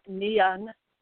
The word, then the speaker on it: Nion: Nion or Nian, according to the Irish, which has the ash tree.